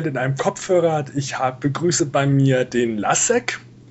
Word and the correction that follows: Leszek: Ich hoffe, ich spreche das richtig. – Leszek wird es ausgesprochen. – Leszek, sorry, das schneide ich.